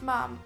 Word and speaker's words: ma'am: Apparently that's pronounced ma'am, as in jam.